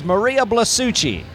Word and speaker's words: Blasucci: Close. Give it a soft C. No, that was right. That was perfect. – No, it's Maria Blasucci. – Blasucci. I know that because I cannot pronounce Italian last names.